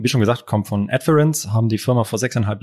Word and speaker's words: Adference: Äh, danke, du hast alles richtig ausgesprochen. Zum einen mein Nachnamen äh, und auch Adference. Da haben wir schon die verrücktesten Kombinationen gehört, aber Adference ist richtig und ist auch richtig.